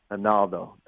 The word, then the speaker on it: Ronaldo: One tweak is in the Portuguese, they pronounce the R with an H, so it's Ronaldo.